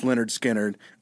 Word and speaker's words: Lynyrd Skynyrd: Um, the album itself, pronounced Leonard Skinner, uh, is number 403 on Rolling Stone's 500 greatest albums list.